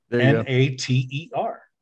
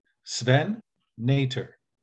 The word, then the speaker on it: Nater: he's Dutch. Sven Nater. Sven Nater. Just like I pronounced it.